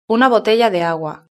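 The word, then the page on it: una botella de agua: Spain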